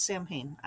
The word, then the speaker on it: Samhain: Angela James, you know, started out running Karina Press, came over from Samhain Books, which they did pronounce Samhain. I asked the owner once.